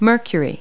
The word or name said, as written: mercury